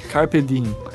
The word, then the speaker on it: Carpe diem: Carpir dia. Carpe diem.